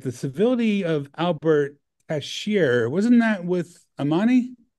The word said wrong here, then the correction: Imani: Am I saying that right? – Imani, yeah. That's Imani.